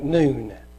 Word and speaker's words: Nun: The next one is, it says N-U-N, but it's pronounced like noon. Noon.